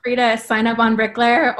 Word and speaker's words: Bricleir: It's pronounced Bricklayer, and it's basically Bricklayer in Old Irish.